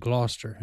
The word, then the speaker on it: Gloucester: That's how you say it, Gloucester. And there's a Gloucester, Massachusetts. It's not spelled that way, but that's how it's pronounced.